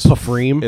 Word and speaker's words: Pfriem: Also, you pronounce it's spelled P-F-R-I-E-M. It's pronounced as Freem. – As Freem, like cream with an F-R.